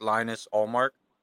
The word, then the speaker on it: Ullmark: it's Linus Ullmark. Am I pronouncing that? Okay. – Yep. I was pretty confident on the Linus. I wasn't sure if there was a trick to the Ullmark.